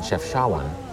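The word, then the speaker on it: Chefchaouen: As it happened, I pronounced the town's name in the two-syllable Anglophone manner. – Chawan? – Chef Chawan. – Oh, okay. – Instead of the more accurate French translation, Chef Chawan.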